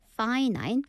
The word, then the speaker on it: finite: The reason I brought this on is because we have infinite, 하지만 유한하는 발음이 finite입니다. 발음이 조금 헷갈리기 때문에요. Infinite, finite, 구분해 주세요.